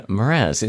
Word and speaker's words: Mraz: This is Jason Mraz. Is that how you say it? M-R-A-Z.